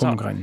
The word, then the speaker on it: Holmgren: Don't pronounce the. – Stay away from the L on mm-hmm. home, on Holmgren. – It's Holmgren. – Not, it's not Holmgren. It's Holmgren. – Holmgren.